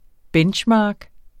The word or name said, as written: benchmark